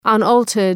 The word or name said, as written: unaltered